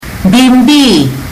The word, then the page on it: bibi: fiji